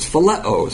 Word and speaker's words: phileo: I can't even spell it in the English, but it's phileo is how you pronounce it, versus agape.